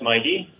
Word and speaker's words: MYTI: So Mighty, the way it's pronounced, you know, it's written M-Y-T-I. – Yes. – But pronounced Mighty.